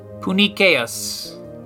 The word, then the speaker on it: puniceus: P-U-N-I-C-E-U-S. So C makes a kr sound in Latin. So Punicius.